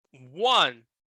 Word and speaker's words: one: One. One. Ó, esse W-A-N vai ficar dessa maneira. One.